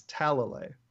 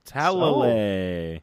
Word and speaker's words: Talalay: Everybody screws it up. It's Talalay. – Talalay. – So, yeah, but it's usually you hear more Talalay and things along those lines.